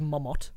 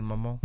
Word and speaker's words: Mamon: No, because they're from, I'm sure it's not pronounced Mamot. – Yeah, Marcin Mamon.